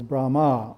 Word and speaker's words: Brahma: The earth region is quadrangular, and the presiding dia is Brahma, long A.